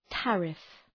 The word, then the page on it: tariff: ‘tærıf